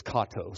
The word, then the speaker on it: Cato: And there's a number of drop-off locations: Brown Shoe Fit, Westlake, Ace Hardware, Standard Supply, Cato. Is that the right pronunciation? Pink Panther, it's Kato, so you'll think of that, what I mean by that, about 2 o'clock this afternoon.